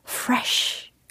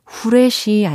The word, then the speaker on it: fresh: 왠지 상쾌한이라고 하면 fresh, fresh 발음 자체가 좀 상쾌하지 않나요? 그죠? 후레쉬 아니고요. 프레쉬 아닙니다. 예, 우리말로 그냥 후레쉬 혹은 뭐 프레쉬 이렇게 쓰여져 있는 경우를 종종 보는데 그대로 읽으면 안 되겠죠?